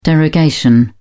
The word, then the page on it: derogation: British English